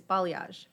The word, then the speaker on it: balayage: It's balayage.